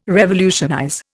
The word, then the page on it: revolutionize: Indian